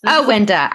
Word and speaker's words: Awinda: Awinda.